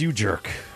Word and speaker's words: U-J3RK5: That was You Jerk, You J3 RK5. The 5 is silent, if you didn't know.